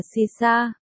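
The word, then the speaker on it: Saoirse: Sao hoa còn được phát âm là Sisa, trong tiếng Ireland có nghĩa là tự do.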